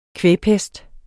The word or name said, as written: kvægpest